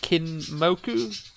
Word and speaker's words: Kinmoku: I was browsing your site before, and the name Kinmoku came up, and I didn't quite know where it sort of came from or what it meant or anything. Did I pronounce it right as well? – Yeah.